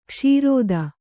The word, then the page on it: ಕ್ಷೀರೋದ: kṣīrōda